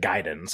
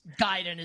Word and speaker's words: Gaiden: And I think it's I always called it Ninja Gaiden, but I think people call it like Ninja Gaiden sometimes It's Gaiden is definitely how you pronounce it.